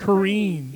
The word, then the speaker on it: Kerene: It's like green. Like literally green. – Green. It's Kerene. Green.